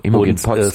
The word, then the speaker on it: Imogen Poots: Imogen Poots, genau.